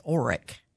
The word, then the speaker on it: OREC: It is OREC. – O-R-E-C. – Right, which is an acronym. It's not really a word.